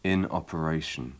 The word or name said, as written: in operation